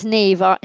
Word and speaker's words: Niamh: Yeah, it's Neve. She's Irish. – N-I-A-M-H, so of course. The letters name don't correspond in any way to the sounds that they make.